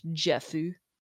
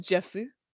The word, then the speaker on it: Jeff: Oh, do you like that the detector's name is Jeff, but it's pronounced Jeffu?